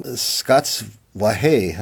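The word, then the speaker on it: Scots Wha Hae: That occurs a lot, particularly in the last movement, which is based on a very famous Scottish song, Scots Wahé, I think is the way it's pronounced, which was for a long time sort of the unofficial national anthem of the Scots.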